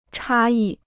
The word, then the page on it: 差异: chā yì